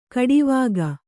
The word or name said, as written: ಖಡಾವಿಗೆ